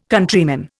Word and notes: Countryman: Indian

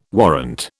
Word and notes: warrant: British English

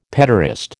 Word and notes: petaurist: American English

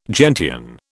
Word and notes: gentian: British English

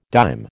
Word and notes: dime: American English

